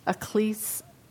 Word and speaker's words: ecclesia: The word church as rendered in the New Testament comes from the term, say it baby, ecclesia, he said it for me earlier, ecclesia, is that how you say it? – Ecclesia.